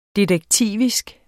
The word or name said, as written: detektivisk